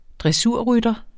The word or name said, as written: dressurrytter